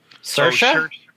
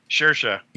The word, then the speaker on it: Saoirse: What's that Irish actress's name that I can never pronounce? – Saoirse? – Saoirse. – Yes.